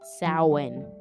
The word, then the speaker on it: Samhain: Pronounced Samhain. That's how you pronounce it. Samhain.